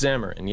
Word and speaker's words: Xamarin: It's- It's Xamarin, yeah.